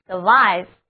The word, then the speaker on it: survive: survive 幸免于、生还、生存的意思。字母 I 要读作它的本音 I。